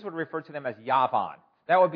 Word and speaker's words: Yavan: Javan. And by the way, how would you say that if you were a Jewish person? Yavan. That's right. You say Yavan.